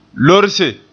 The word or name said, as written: lorse